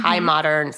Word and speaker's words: Chai Modern: Also, I got him a challah tray from, now, how do I pronounce this now? Chai Modern? I think it's Chai Modern, right? – Chai, C-H-A-I. Yeah. Chai Modern, spelled chai.